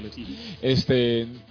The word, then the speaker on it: Lives: Se llama así, Bruce Wayne Lives. – Lives. Se pronuncia Lives.